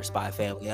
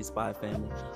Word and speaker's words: Spy x Family: If you're a family-esque person, watch Spy X Family or Spy Family. Are they doing the Hunter Hunter route? Am I supposed to pronounce the X?